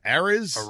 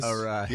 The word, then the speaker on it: Arraez: Arraez. – Yeah. I mean, Jesus Christ. But Luis, how do you say it Arraez. – Again? – Arraez.